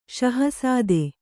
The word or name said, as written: ಷಹಸಾದೆ